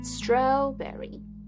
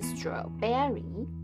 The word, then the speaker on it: strawberry: 首先发音的地方， berry 在这三个单字中都不是重音，所以我们不要加重 berry 的发音。 strawberry 而不是 strawberry， cranberry, raspberry 同理可证，也是一样的。